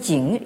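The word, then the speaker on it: Jing (井): en forme de ce caractère qui se prononce donc en chinois moderne Jing et qui veut dire le puits.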